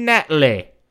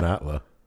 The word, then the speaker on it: Natla: Jacqueline Natalie, which is not how anyone else in the rest of the game pronounces that name. It's Jacqueline Natla or Natla? – Natla.